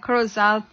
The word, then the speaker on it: Corozal: Well, and yes, you know, after the Corozal program, our guest speaker will be here in Bullet Tree.